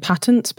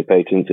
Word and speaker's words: patents: Well, in the UK, we would say patents.